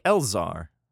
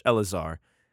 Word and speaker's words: Eleazar: Apparently, Eleazar is like Elzar, pretty much, Eleazar. I've been saying that wrong for a while.